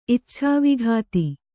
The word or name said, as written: ಇಚ್ಛಾವಿಘಾತಿ